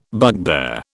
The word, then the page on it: bugbear: British English